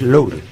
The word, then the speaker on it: Lowry: Lowry, pero no, es Lowry.